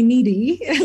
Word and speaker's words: Nidhi: So my family called me Nidhi, and Nids came from Nidhi being really hard for most of my US clients and friends to pronounce. So they either call me Nidhi, like I need something.